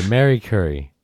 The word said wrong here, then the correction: Marie Curie: Marie Curie? – I always say it wrong. By Marie Curie.